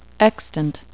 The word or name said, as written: extant